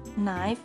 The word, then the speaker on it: knife: Knock, knife, new is a silent K.